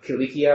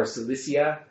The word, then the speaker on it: Cilicia: Cilicia or Cilicia. Oh. Even though it'd be a hard C rather than a soft C.